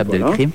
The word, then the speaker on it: Abdelkrim: Abdelkrim. – Oui. – Je ne sais pas si je le prononce bien. Si, c'est ça, c'est Abdelkrim.